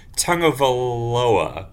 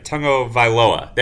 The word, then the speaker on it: Tagovailoa: I promised myself I would try to pronounce this right. I looked it up. It's Tua Tagovailoa. I think I got that right. Tagovailoa. There we go. And apparently the tongue is in there. So it's Tagovailoa for those listeners who don't know.